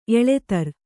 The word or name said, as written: ಎಳೆತರ್